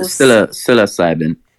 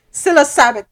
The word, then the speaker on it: psilocybin: P-S-I-L-O. – Psilocybin. – Psilocybin.